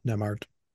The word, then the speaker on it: Nembhard: Am I saying that right? Nembhard? – Nemard.